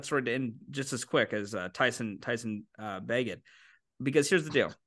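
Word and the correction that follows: Bagent: I don't even know if I'm saying that right, and I hope I am. – It's Badgin. It's Badgin.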